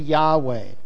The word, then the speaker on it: Yahweh: People who study in schools a lot of time believe that the proper pronunciation of Jehovah is actually Yahweh.